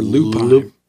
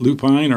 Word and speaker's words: lupine: Or lupine. Depending on how you say it, lupine or lupin.